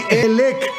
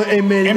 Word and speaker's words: Emelec: A ver, di Elec. No, Emelec.